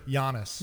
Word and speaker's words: Giannis: That is all what went to the Bucks for the Heat to get Giannis and Bobby Portis. – That's I like that you pronounced the G. – Isn't that? Don't you need to do that? – No, it's a Y sound.